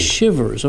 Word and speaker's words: Chivers: Chivers, like your cold. – Chivers, okay, yeah.